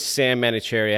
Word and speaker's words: Sahm Manouchehri: And my name is Sahm Manouchehri. I have unnecessary H's in both my names.